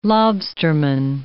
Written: lobsterman

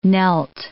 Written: knelt

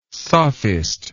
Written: sophist

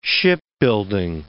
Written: shipbuilding

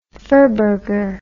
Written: furburger

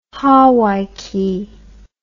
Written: hawaiki

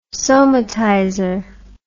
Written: somatizer

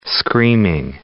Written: screaming

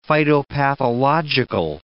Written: phytopathological

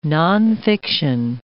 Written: nonfiction

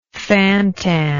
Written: fantan

